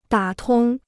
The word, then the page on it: 打通: dǎ tōng